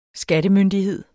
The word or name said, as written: skattemyndighed